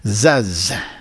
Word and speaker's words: Zaz: Zaz. Zo moet je net uitspreken, denk ik, die naam. Z-A-Z. Zaz.